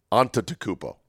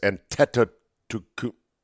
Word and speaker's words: Antetokounmpo: Giannis Antetokounmpo, I think I'm saying that right, Antetokounmpo. It's a very hard one. I used to say Antetokounmpo. No, it's Antetokounmpo.